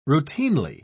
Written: routinely

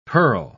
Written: purl

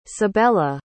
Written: sabella

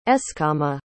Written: eskama

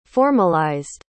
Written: formalized

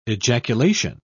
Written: ejaculation

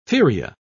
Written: feria

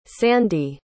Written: sandi

